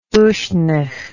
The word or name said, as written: uisnech